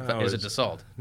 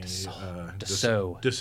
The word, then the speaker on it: Dassault: Is it a different company that's doing it, or is it Dassault? – No, it's... – I'm not saying that right. It's not Dassault. – It's Washington's company. – Dassault. I shan't make that mistake again.